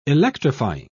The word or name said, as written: electrifying